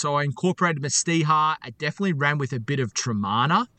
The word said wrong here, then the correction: Teremana: if I'm saying that correctly, tequila. – Teremana. Yeah. That's the rocks tequila. – Yeah. Teremana. There you go.